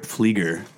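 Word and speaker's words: Pflieger: Paper Rip Flieger. Pronounce the P on paper rip, silent P for my last name, but it's good to be back.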